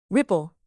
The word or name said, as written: ripple